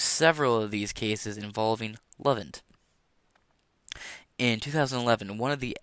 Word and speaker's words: LOVEINT: It's spelled L-O-V-E-I-N-T, Lovent, for Love Intelligence.